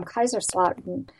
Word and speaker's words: Kaiserslautern: Today's show is CHDs Around the Globe, Germany, and we're talking with Debbie Gilmore from Kaiserslautern.